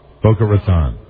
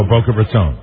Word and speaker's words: Boca Raton: Oh, and then the important, it's called Boca Raton. That's a long O in Raton. – Boca Raton. – Manton. – I thought it was Boca Raton. Long O. So. Oh, Boca Raton. – Mantalk, I just said it wrong. Mantalk 640 is WMEN. How do you say it again? – Boca Raton.